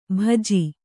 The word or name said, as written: ಭಜಿ